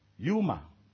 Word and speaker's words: pneuma: In the New Testament, the Greek word is pronounced for wind is, breath is, pneuma. It's pronounced as P-N-E-U-M-A, pneuma.